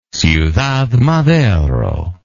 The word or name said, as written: ciudad madero